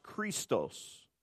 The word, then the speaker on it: Christos: But I'm told that it's pronounced Christos.